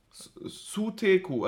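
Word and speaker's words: Suteku (ステーキ): Die, haben, die sagen Suteko. Sute Suteku, also Steku. Ach so. Suteku. – Weil die können ja kein St aussprechen.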